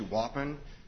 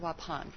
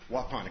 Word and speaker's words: Waupun: First, a month after the case was filed, Mr. Olson was transferred from Columbia Correctional Facility to Waupun Correctional Facility. – We pronounce it Waupun. – Waupun, excuse me.